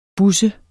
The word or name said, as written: busse